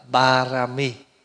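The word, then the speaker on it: Pāramī: Tiếng Phạn đọc là Parami, tiếng âm mình đọc là Ba La Mật.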